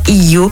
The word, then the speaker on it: Hiyo: Elles s'associent pour donner naissance à Hiyo, H-I-Y-O. Alors, comment vous le prononcez? On ne sait pas. Toujours est-il que phonétiquement, ça se prononce Hiyo.